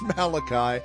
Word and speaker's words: Malachi: It just depends on how you get the syllable on the emphasis, right? It's Malachi, and that's where we're at today as we close out the week.